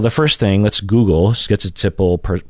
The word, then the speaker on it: schizotypal: So the first syllable is skit versus skit or ski. So you will hear all three of the, so you have that first syllable, right? Some people pronounce it skit, like schizotypal. You hear that T in there, schizotypal, or ski without a T sound, so schizotypal.